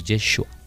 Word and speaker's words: Yeshúa: Suena así, sería como Yeshúa, pero es Yeshúa.